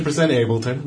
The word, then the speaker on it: Ableton: and it is pronounced Ableton, and we have confirmed that with many Germans. – Oh, nice. – It is definitely 100% Ableton.